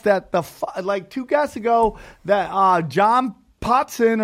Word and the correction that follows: Potash: John Potash.